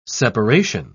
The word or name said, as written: separation